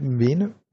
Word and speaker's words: Mwene: Bei euch spielen ja auch ein, zwei ehemalige vom VfB, die sich ja vor allem Philipp Mwene. Spricht man ja so aus, gell? Ja, doch.